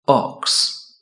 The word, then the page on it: ox: British English